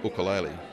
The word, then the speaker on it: ukulele: And that's how the Hawaiians pronounce it today, ukulele.